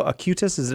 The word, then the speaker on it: Acutis: I want to talk about that boy that's about to be beatified in Assisi, Carlo Acutis. Did I say his name? – Yeah, that's right.